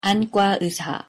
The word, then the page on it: 안과의사: Korean